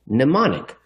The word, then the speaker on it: mnemonic: Jeff, you don't often see English words with the M and N in the beginning. And when you do, the M is silent, mnemonic.